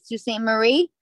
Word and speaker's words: Sault Ste. Marie: For some people, when I was on, I hope I'm saying it right, Sault Ste. Marie. – Yep. – Oh, you nailed it.